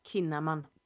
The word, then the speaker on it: Kinnaman: Det är han som är på liksom, tillsammans med Melinda Kinnaman. – Kinnaman. – Kinnaman, oh my god. – Yes, jag fick rätta dig på ett uttal.